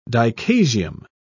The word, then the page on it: dichasium: British English